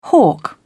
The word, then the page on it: hawk: British English